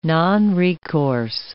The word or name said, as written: nonrecourse